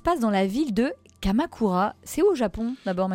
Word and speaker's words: Kamakura: Kamakura. – D'accord. – Oui, Kamakura, c'est le R et le L se prononcent à peu près identiquement en japonais.